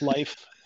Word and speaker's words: Leif: Almost everyone has a friend with a name spelled like mine, and they pronounce it Leif or Leif. And the simple fact is that they're all mispronouncing it. It's Leif. Anyone who's studied Germanic languages or Scandinavian languages know the E-I, I-E combination.